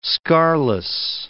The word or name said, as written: scarless